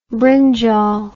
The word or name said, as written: brinjal